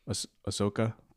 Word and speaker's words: Ahsoka: I'm not interested in anything except for Osaka. Nope. How do you pronounce her name? – Ahsoka with Rosario Dawson.